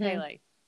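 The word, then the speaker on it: Pelle: Pele. – Pele? – Mm-hmm. – Pele. – It's P-E-L-L-E, because I was watching with subtitles. So I think that that pronunciation is fine.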